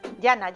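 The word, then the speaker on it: Jana: Ahí se te iba a preguntar, ¿que, que Yana se dice así, no Ana? – Yana, Yana. – Yana, Yana, en ok. catalán es Yana.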